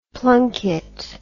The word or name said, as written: plunket